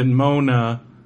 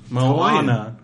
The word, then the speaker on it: Moana: It says the English indication might be to pronounce it Mona. The name is actually three syllables. Moana, but that's wrong too. Ah, you're right. Moana. – Moana. – But it looks like Mona.